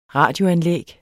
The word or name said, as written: radioanlæg